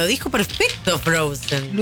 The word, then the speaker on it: Frozen: Me dice, Ricardo, consultá si viene Frozen, se dice. – Sí, muy bien. – Frozen.